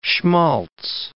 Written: schmalz

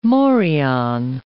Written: morion

